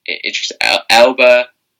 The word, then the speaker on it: Idris Elba: I mean, you got like John Cena, you got Idris Elba.